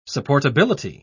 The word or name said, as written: supportability